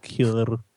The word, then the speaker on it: Heuer: a $5,000 Tag Heuer, or whatever, however you pronounce that, really prestigious brand. – Heuer.